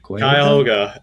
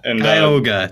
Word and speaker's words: Cuyahoga: But the one for me, I'm going to mispronounce this. It's Cuyahoga? – Cuyahoga. – Cuyahoga. Thank you.